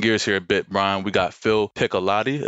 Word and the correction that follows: Pichotti: Excuse me if I... Pichotti. – We got, I believe I'm saying it right because I asked him. It's Pichotti, I think.